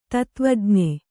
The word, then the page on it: ತತ್ತ್ವಜ್ಞೆ: Kannada